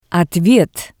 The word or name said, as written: otvet